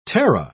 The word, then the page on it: terra: British English